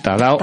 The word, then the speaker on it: Tadao: Con estas palabras del propio Tadeo Ando, damos la bienvenida a Fanny Sánchez. – Buenas tardes. – Buenas tardes. Eh, Tadao, a ver si lo digo bien, Tadao, Ando.